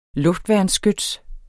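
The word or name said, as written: luftværnsskyts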